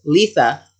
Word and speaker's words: Litha: So the summer solstice, or Litha, I had to Google how to say that because apparently I've been saying it wrong for like 10 years. – What which did is you fine. used to say it? – Oh. Uh, but it's Litha, which sounds like Lisa with a lisp.